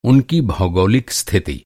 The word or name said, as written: भौगोलिक